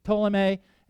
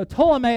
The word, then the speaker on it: Ptolemy: There are four principal generals, and two of them are the ones that are most important, Ptolemy and Seleucid. Don't pronounce the P, just so you know. And so Ptolemy of the Egyptians and Seleucids of the north of Assyria, they start fighting.